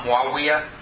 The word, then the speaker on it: Muawiyah: Muawiyah.